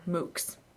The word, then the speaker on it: MOOC: So do we want to start with, we pronounce this MOOC? – It is. Yeah, it's pronounced MOOC.